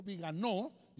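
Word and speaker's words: Viganò: Entonces, el título de esta noticia dice que el arzobispo Viganó, lleva acento en la O, advierte a Donald Trump sobre un complot para someter a la humanidad.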